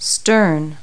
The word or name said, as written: stern